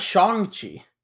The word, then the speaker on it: Shang-Chi: He's actually pronounced Shang-Chi. Apparently, he's just been saying it white the whole time.